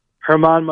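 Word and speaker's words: Germán: I mean, uh, very preliminarily, preliminarily, I'm looking at I mean, John Means from Baltimore, German, I think you pronounce it German, Marquez, Colorado, Michael Pineda, Minnesota, Kyle Gibson, Texas, Jon Gray, Colorado. Those are the teams I'm looking to be picking from. – Herman Marquez. I like that.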